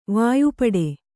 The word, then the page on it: ವಾಯುಪಡೆ: vāyu paḍe